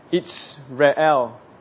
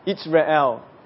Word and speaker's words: Jezreel: Jezreel is actually pronounced Itzrael. Itzrael. And Israel is pronounced Israel.